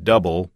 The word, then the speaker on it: Dubbel: D-U-B-B-E-L is close to the pronunciation of double, and absolutely not like you guys pronounced it in the show. and Gert says, another episode was hilarious. Your guest pronounced Roselaire, R-O-E-S-E-L-A-R-E as Roselaire, which is wrong.